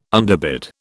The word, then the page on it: underbid: British English